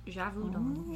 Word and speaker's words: Gévaudan: Gévaudan.